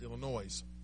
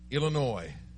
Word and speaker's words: Illinois: And for some reason, everybody thinks that Illinois down there is pronounced Illinois. And she always says, the S is silent. It's silent. I'm just stuck here in Illinois, in a little small town.